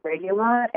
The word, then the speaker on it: Regulat: Yes, it's called Rex Regulat, and it's spelled R-E-C-H-T-S. It's from Germany, so that's why it's got kind of a strange name. And then the Latin part of it is R-E-G-U-L-A-T, Regulat.